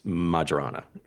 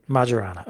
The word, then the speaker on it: Majorana: And Merriam-Webster agrees with Jana that it's Majorana. – Majorana, okay.